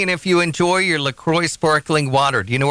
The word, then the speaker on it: LaCroix: Officially, it's pronounced LaCroix.